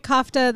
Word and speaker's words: kofta: It's not for everyone, but they just made some really awful tofu, but what they did really well was, tell me if I'm pronouncing this correctly. Is it called kofta? It's fake lamb. – Yeah. Well, yeah, it's like a kofta.